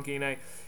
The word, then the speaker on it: Guyenet: It's second name Guillanet, is it? How do you pronounce Stephan? Yeah, yeah, that's, right. Yeah, Stephan Guyenet. Steph- I call him Stephen at start, but really Stephan is what he's called.